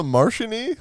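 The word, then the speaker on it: Martin E: You're going to miss a Martian-y? Yeah. Like, the M meant for Martian, M for Martian. – Yeah. – When you're going to miss me. – It's spelled M-A-R-T-I-N space E, the letter E.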